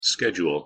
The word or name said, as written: schedule